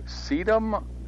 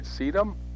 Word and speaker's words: sedum: Is it okay to move sedum now or too late already? I think I pronounced that right, sedum? – Yep.